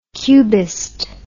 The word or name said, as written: cubist